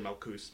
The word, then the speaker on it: Malkut: So they'll say Malkuth instead of Malkut or Malkus.